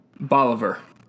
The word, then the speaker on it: Bolivar: That's how you say it, right? Bolivar? – Bolivar. – Bolivar. I was close, man. – Enough. – I was close. I've actually been there, and I almost called it Bolivar.